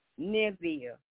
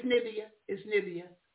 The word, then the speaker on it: Nineveh: Nineveh. I'm not even pronouncing it. – No, it's Nineveh.